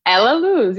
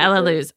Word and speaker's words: Elaluz: Is it Elaluz? How do you say it? – Elaluz. You said it perfectly. – Elaluz.